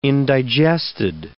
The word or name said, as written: indigested